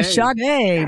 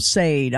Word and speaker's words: Sade: Yeah, Sade, man. Yeah, exactly. Yeah, I said it wrong on live on radio. I called him Sade. I'm like, oh, I never lived that one down. So, yeah, Sade.